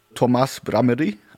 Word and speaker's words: Tomas Brameri: If I'm informed correctly, you have a trio, which is for a very long time consisting of the double bassist Tomas Brameri. I hope I'm pronouncing the name right.